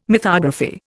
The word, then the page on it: mythography: Indian